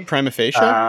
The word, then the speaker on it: prima facie: Prima facie? – I guess so. Yeah. – Legal term? – Yep. Prima facie. What do you know? That's how it's pronounced.